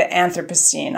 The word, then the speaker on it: Anthropocene: Yeah, it's pronounced differently depending on which side of the Atlantic you're on, actually. – What is it on our side of the Atlantic? – It's usually pronounced the Anthropocene on our side of the Atlantic.